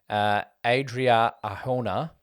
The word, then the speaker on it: Adria Arjona: I did say Mira Sorvino, but it went to Adria Arjona from Morbius.